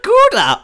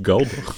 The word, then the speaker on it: Gouda: äh, Matty sagt die ganze Zeit, Oh man, that's some good Gouda! Und der Typ sagt immer so, Jo. it's, it's pronounced Gouda.